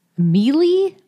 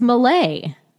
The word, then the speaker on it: melee: Is that how you say it, Malay? – Mealy? It's Malay.